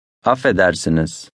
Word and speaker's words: Affedersiniz: Affedersiniz.